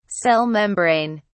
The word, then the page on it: cell membrane: British English